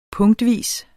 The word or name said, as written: punktvis